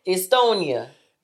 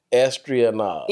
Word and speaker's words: Estonia: You just butchered up that place. Estonia. – Estonia. – Estonia. There's no R in there.